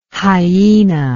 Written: hyaena

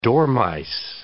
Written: dormice

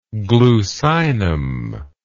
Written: glucinic

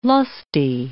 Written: lusty

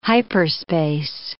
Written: hyperspace

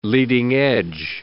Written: leading edge

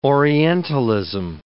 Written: orientalism

